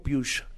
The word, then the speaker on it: Puig: Y el tercero es María Alonso Piush. Se escribe Puig, pero se dice Piush.